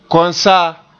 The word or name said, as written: konsaa